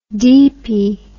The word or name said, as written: deepie